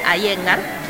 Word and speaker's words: Iyengar: Iyengar es una palabra medio rara, yo sé, se escribe con I latina, Y-E-N-G-A-R, se dice Iyengar.